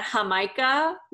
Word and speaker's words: Jamaica: So it's with a J, so we pronounce J's like H's. Mm-hmm. It took me years for my brain to like compute that Jamaica was Jamaica.